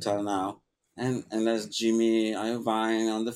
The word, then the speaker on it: Iovine: Iovine? – Jimmy Iovine.